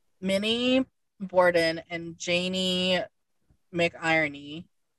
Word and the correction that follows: McInroy: Did you say McIrony? – Yeah, I think I said it wrong, but I kind of wish it was McIrony. It was McInroy.